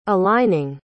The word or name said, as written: aligning